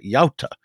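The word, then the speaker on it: Jałta: Yauta, and he wonders what this means. Does Yauta mean fate? And in fact, it's the Polish pronunciation of Yalta, which for generation is a synonym for betrayal.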